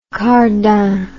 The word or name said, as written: cardin